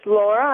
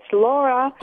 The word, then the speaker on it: Laura: Yeah, I just want to say my name is Laura. I think they maybe they didn't understand, but it's Laura.